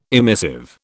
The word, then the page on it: emissive: British English